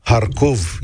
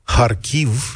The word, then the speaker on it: Harkiv: Harkov este pronunția rusească și câtă vreme uh, Uniunea Sovietică a fost acolo, i s-a spus Harkov. Harkiv este pronunția ucraineană, așa îi zic uh, ucrainenii. Sunt mici diferențe între cele două uh, țări și între cele două limbi dar dacă este în Ucraina, e cum zic oamenii aia, ucraineni, Kharkiv, da?